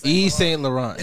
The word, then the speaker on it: Yves Saint Laurent: It's Yves Saint Laurent. – Yves Saint Laurent.